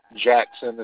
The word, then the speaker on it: Jackson: Jackson is spelled J-A-C-K-S-O-N.